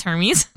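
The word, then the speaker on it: Hermès: Shout out to Hermes for that. – Who is it? – Hermes? Yep. – Are you sure it's not Hermes? – I literally asked that just in case. It is Hermes.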